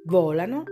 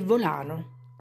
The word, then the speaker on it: volano: Oggi parliamo di volano e volano. Volano da volare. Volano è un gioco. Nel primo caso l'accento cade sulla O, nel secondo sulla A.